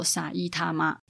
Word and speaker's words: 埼玉: 那一样就是念作萨伊他妈这样子。